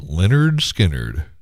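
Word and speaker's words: Lynyrd Skynyrd: Tuesday's Gone from pronounced Leonard Skinner.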